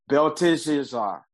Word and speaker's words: Belteshazzar: I've been trying to get that pronunciation right, because I've been pronouncing it like the same way the king of Belshazzar name is pronounced, but it's Belteshazzar.